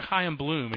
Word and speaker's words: Chaim Bloom: Pretend you're Chaim Bloom and you're arguing that in the room.